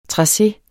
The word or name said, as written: trace